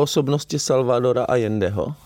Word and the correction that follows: Allende: Jestli jako, to říkám, vyslovuji to Salvador, Ano, je to Allende, ano, Allende.